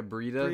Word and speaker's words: Breida: Yeah, Breida.